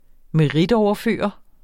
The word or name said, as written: meritoverføre